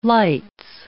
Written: lights

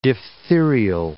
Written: diphtherial

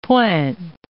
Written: pointe

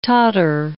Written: Tatar

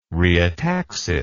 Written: rheotaxis

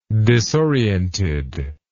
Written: disoriented

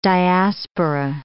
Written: diaspora

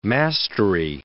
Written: mastery

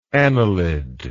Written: anilidic

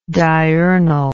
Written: diurnalness

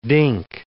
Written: dink

